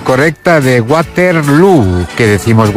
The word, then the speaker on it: Waterloo: Bueno, lo primero, Waterloo se pronuncia igual que Feijóo.